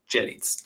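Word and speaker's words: Zselic: Ja, und zwar ist das hier, das ist der Sternen, das ist die Sternenwarte in äh, Selic. Habe ich das richtig gesagt? Selic, ne? Selic, ja, genau. Selic, aber sehr gut. Jellits, Jellits. Genau.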